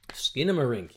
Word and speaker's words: Skinamarink: It's called Skinamarink. – Skinamarink, yes, hugely, hugely profitable and and popular at the moment.